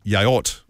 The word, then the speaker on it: yaourt: The French word for yogurt is actually yaourt. Well, I think I'm saying that right. Y-A-O-U-R-T.